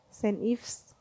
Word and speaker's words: St. Ives: Steve's apa sih bacanya Kenora deh ya pokoknya Ives itu yang Steve Saint Ives kan Saint Ives St. Ives yaitu Saint Ives ya Allah noraknya Cu,